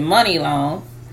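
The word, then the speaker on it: Muni Long: Mooney Long, I think she pronounces it Money Long, She talks about how she has been underpaid as a songwriter in the industry.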